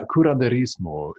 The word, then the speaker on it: curanderismo: The closest I would think would be the Curandismo, Mm-hmm. And you mm-hmm. could probably pronounce that a lot smoother than I just did. – It's a yeah, curanderismo in, in Spanish.